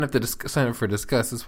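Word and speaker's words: Disqus: Just sign up for Discuss. Is that how you pronounce that, guys? Discuss? – For a long time, I was saying Discus, but I believe it is Discuss, yes.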